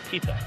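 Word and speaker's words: Pitta: Yeah, Dennis Pita, I believe, is the native pronunciation. Pita.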